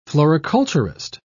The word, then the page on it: floriculturist: British English